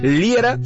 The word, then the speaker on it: Liera: Y también si quieres seguirme y que yo te la comparta directamente, búscame por mi nombre, Fernando Liera, se escribe L-I-E-R-A.